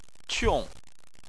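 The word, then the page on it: qiong: tone 4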